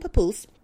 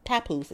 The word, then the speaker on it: Papoose: It was her and her husband Papoose, Papoose, excuse me, let me say his name right.